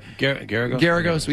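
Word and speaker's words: Geragos: There's a rumor that came out yesterday, started by Colin Kaepernick's lawyer, Mark Garago. How you pronounce his name? The OJ guy? – Garagos, either way.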